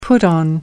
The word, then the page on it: put on: American English